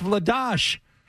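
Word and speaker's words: Vladař: And Vladar? – He knows the players. – Yeah. – But we've heard Vladash, like it's supposed to be pronounced Vladash.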